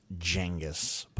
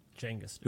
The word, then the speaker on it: Genghis: It's pronounced Genghis. – Genghis.